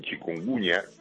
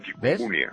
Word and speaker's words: chikungunya: Bueno, ahora en los medios le ponen chikungunya, pero normalmente lo pronunciábamos un poco como si fuera en catalán, chikungunya.